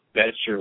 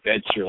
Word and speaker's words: Bettger: It's by a guy by the name of Frank Betger, B-E-T-T-G-E-R. Pronounces it Betcher like Bet Your Life. What's interesting is the guy sold life insurance after he was in baseball. He sold life insurance. B-E-T-T-G-E-R, Betcher like Bet Your Life.